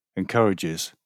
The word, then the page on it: encourages: British English